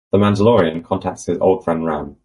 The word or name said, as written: contacts